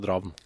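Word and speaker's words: Drafn: Ja, for det hadde, vi hadde en diskusjon på det der, hvordan uttaler man, altså det skrives... Draffen på en måte, men uttales Dravn.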